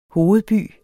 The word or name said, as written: hovedby